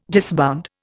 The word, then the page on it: disbound: Indian